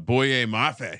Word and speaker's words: Boye Mafe: And he's like, oh, hey, dude, our guy, Mr. Boye Mafe. Is that how you say it? – Yes.